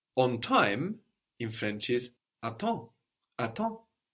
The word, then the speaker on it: à temps: The M on the end of the syllable there gets completely swallowed up. À temps, and the P and the S on the end there also completely disappear. So what looks like attempts is à temps, attends, à temps, attends, and that means on time, on time.